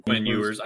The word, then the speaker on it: Quinn Ewers: Quinn Ewers.